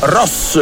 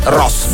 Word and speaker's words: Ross: In romagnolo si dice Ross. Allora, è un comune di 12.295 abitanti. – Interessava provincia... solo la pronuncia, locale. – Allora, scusate, Ross. Ross. Fine.